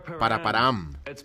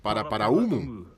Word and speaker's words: Paraparaumu: Empiezo yo. Paraparam. Se pronuncia paraparaumu. No sé de dónde viene el paraparam.